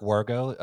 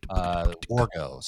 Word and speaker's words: Wargo: I just go by my name, Eric Wargo. That's E-R-I-K. Wargo, spelled like it sounds, W-A-R-G-O.